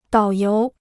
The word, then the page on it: 导游: dǎo yóu